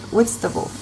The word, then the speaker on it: Whitstable: Um, we're currently in the UK, in Whitstable.